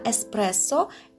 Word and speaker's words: эспрессо: будьте аккуратны и всегда старайтесь произносить слово правильно «эспрессо» и, как вы слышите еще раз, повторяю, что звук «р» в этом слове произносится твердо.